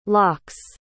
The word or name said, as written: locks